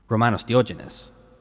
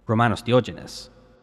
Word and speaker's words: Romanos Diogenes: Romanos Diogenes. – Sorry, come again? – Romanos Diogenes.